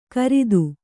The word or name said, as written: ಕರಿದು